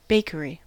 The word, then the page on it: bakery: American English